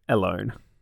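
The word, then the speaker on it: Ellone: Yeah, like Alone, but with an E, so Alone. Anyway. So, his surrogate sister, Alone, has this ability to have people's consciousness be sent back in time to people that she has met before or already knows.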